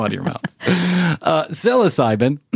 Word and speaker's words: psilocybin: One of my friends, a couple of months ago, about a year ago, decided he was going to do uh, seek asylum, seek asylum, seek Psilocybin.